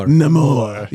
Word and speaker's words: Namor: and even seeing Namor, right? Is that how they pronounce it in the movie? – Namor. – Right? I can't roll my tongue, man.